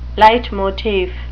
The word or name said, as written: leitmotif